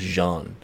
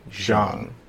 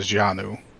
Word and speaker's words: Jeanne: I think it's pronounced Jean. – Yes. Jean. – Yeah. Jean. – Yes, because they did the Japanese pronunciation as Janu.